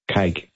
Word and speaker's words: kei: The Keika, K-E-I. Cake. – The cake car. – Yeah. As in K-A-Y, but spelt K-E-I. – What do you drive? I drive a cake car.